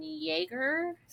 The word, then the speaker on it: Yeager: I'll have to check, but he is the American VA for Inosuke and Demon Slayer, and also Eren Yeager. Is that how you pronounce it? In Attack on Titan? – Yeah.